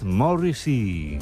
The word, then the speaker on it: Morrissey: Por eso ha creado este número musical llamado Morrissey, o como se pronuncia en inglés, Morrissey.